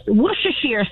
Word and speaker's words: Worcestershire: All right, first of all, it's pronounced Worcestershire sauce. – Yay, well done.